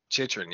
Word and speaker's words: Chychrun: And I think the fact of other fans coming in to play well, Chachurkin, does that name sound right? Did I say that right? You're laughing. No, how you say it? – What's the first name? – Jacob. – Chychrun. – Yes.